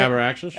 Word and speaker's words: Abraxas: It's Abraxas. Is that how you say it? – Abraxas.